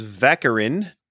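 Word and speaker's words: vacherin: Our next word is vaccarin. Which is rhymes with saccharin, and it is a sweet mixture of meringue and whipped cream.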